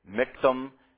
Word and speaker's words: michtam: Understand that that's a michtam, as you'll see it in some of your conventional translations. Michtam is the way you would pronounce that.